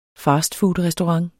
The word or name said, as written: fastfoodrestaurant